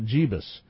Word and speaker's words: Jeebus: Jeebus. And I didn't mispronounce that. That's what it says, J-E-E-B-U-S. Engel and Brown plan for the Charlotte event to launch a new national movement to oppress gay people, unquote.